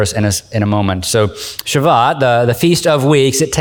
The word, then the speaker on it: Shavuot: You may have heard the Jewish word Shavuot, perhaps pronounced Shavuot, right? Shavuot, Shavuot, Feast of Weeks.